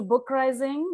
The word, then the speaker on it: BookRising: Hello and welcome to BookRising, which rhymes with uprising.